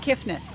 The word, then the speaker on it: Kiffness: Did I say that right? The Kiffness? – The Kiffness. There's okay. No T.